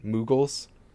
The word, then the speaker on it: Moogles: Moogles? – Yeah.